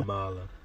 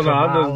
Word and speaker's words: Kamala: Did I say your name? – Kamala. No, Kamala. No, I'm just teasing the fact that, you know, Kamala. – So we space it out, yeah, a little bit.